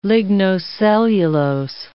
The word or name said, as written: lignocellulose